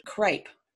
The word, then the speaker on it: crape: Well, that material that all the mourning clothes were made from was a fabric called crepe. Pronounced the same way as the material crepe, but spelled C-R-A-P-E, as opposed to the other spelling of C-R-E-P-E.